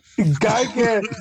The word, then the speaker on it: Gigan: I can't say his name. – Gigan. We've been saying his name right the entire time.